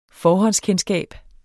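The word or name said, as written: FORHÅNDSKENDSKAB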